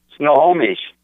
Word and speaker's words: Snohomish: Snohomish.